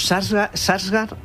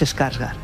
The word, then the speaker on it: Skarsgård: Los Sarsgaard no es Skarsgård.